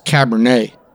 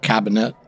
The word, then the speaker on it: Cabernet: That's Cabernet, not Cabinet.